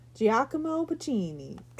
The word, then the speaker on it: Giacomo Puccini: It's loosely based on, I'm going to butcher his name, Giacomo Puccini. – That was great.